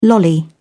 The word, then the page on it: lolly: British English